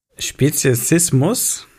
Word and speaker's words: Speziesismus: Speziesismus habe ich es richtig ausgesprochen? So ungefähr. – Äh, ein, genau, richtig, ja.